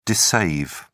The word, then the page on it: dissave: British English